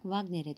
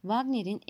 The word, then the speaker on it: Wagner: Onu bir yumuşak W şeklinde telaffuz ediyor. Yaklaşık olarak doğru sesin çıkması için bu ismi harf harf V, A, R, G, N, E, R olarak söylemeliyim. Dört Ring Operası Wagner'in en büyük eserleridir.